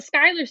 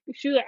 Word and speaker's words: Schuyler: I have seen Hamilton, and it's S-C-H... – U-Y-L-E-R. That's how her name is. Yeah, it's the Skylar sisters. And if you look it up, it's spelt that way. Hold on. – Wow. – Hold on. – Blowing my mind here. – Yeah, the Skylar sisters. S-C-H-U-Y-L-E-R. So it's Van Skylar.